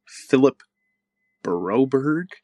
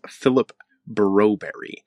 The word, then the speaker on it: Philip Broberg: And every time David Staples says Philip Broberg, he pronounces it as Philip Broberry.